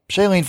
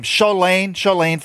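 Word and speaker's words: Shalane: Shailene Flanagan. And she's... – Who? – I don't know how to say her name. Shailene Flanagan. Thank you. It's Elaine, like L-A-N-E.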